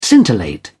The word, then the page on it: scintillate: British English